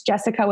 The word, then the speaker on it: Jessika: jessica with a k so j-e-s-s-i-k-a P H I L L I P S.